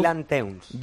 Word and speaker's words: Teuns: Dylan Teuns.